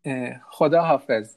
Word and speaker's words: Khodafez: No, Khodafez. It means that God bless you. – Oh, how do you say it again? – Khodafez. – Khodafez. – Yes, Khodafez.